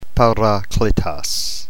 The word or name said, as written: parakletos